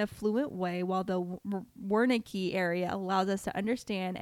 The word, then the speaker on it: Wernicke: Wernicke's. Why is there a Z at the end of that pronunciation? There's no Z whatever, the Braca and Wernicke area.